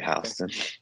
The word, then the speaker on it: Houston: It's spelled Houston, but they say Houston.